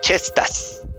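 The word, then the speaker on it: Cestvs: se pronuncia Chestas. chestas. porque el nombre es romano.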